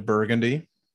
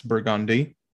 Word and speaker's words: Burgundy: Because there's many of videos on, like, we have a street named Burgundy, but that is not how it's pronounced. It's Burgundy.